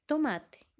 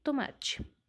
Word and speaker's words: tomate: O, a primeira sílaba to se pronuncia igual que a última sílaba, t, tomate, e não tomate, ok?